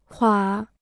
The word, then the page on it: 垮: kuǎ